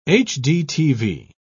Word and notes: hdtv: British English

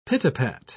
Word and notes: pit-a-pat: British English

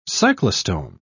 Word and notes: cyclostome: British English

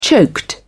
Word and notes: choked: British English

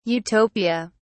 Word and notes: Utopia: British English

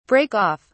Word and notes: break off: British English

